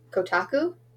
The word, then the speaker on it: Kotaku: But, and I mean, it's funny you ask about spooky games, because I found on Kotaku.